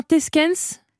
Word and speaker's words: Theyskens: Alors, est-ce que je prononce bien Teskens? Ça s'écrit T-H-E-Y-S-K-E-N-S. – Oui, je pense que c'est bien.